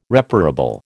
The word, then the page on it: reparable: American English